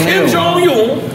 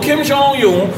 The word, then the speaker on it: Kim Jong-un: Kim Jong-un. That's how you say it? – Kim Jong-un. – I'm like Mr. Lee right now. Little Kim Jong-un gonna come to Drink Champs.